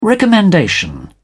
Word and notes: recommendation: British English

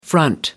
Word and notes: front: American English

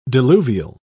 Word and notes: diluvial: British English

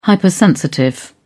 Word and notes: hypersensitive: British English

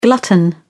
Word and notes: glutton: British English

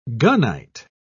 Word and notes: gunite: British English

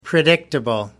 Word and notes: predictable: American English

